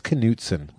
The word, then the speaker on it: Knutson: No, it's spelled Knutson, and my wife said, that's Knutson. Mm-hmm. I was like Knutson? – Like Knott's Berry Farm.